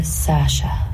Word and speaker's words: Saoirse: It's Saoirse, but it's easier for Americans and English people to say Saoirse or Sasha.